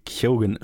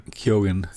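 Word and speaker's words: Keoghan: Barry Kyogen spricht man es, glaube ich, Kyo Kyogen, okay. – Kyogen, ziemlich sicher.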